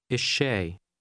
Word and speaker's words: الشاي: The part that means the in Arabic sounds slightly different depending on what sounds follow. You'll learn how to say it in context. Now say again, the tea. – Ishay.